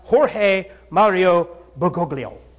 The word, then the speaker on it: Jorge Mario Bergoglio: His real name is George Mario Bergoglio. And I think it's pronounced Jorge Mario Bergoglio.